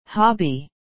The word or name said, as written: hobby